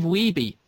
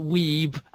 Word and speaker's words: Wiebe: Wiebe. It is Wiebe, folks, not Weeb, as it is incorrectly pronounced by many of the characters in the movie.